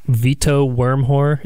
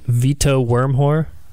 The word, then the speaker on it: Wormhoor: Vito Wormhoor is how you pronounce the name. He say again. – Vito Wormhoor. – Worm. – In the pronunciation, that had an H, like Hoor. – Okay. – It looks like Worm Gore, but I guess it's pronounced Wormhoor.